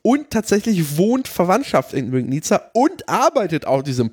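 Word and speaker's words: Legnica: Aber dieses L hat einen Strich. Deswegen wird es Łęgnica ausgesprochen. Und ja, nicht Łęgnica oder was hat er gesagt? Łęgnica. So ein Bullshit.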